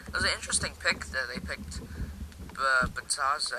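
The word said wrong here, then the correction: Bitadze: or however you pronounce his name. – Bidazzi.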